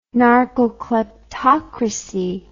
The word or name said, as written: narcokleptocracy